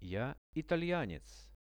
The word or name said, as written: ja italianec